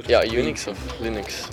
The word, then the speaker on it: Linux: I pronounce Linux. – 142. – As Linux.